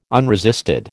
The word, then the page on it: unresisted: American English